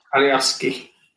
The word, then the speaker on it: Alioski: Alioski.